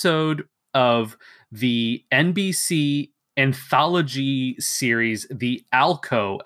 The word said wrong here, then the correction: Alcoa: Hour, or The Alcoa Hour. It's spelled A-L-C-O-A, which was one of these like mid-50s kind of variety show sort of things, right?